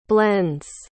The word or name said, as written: blends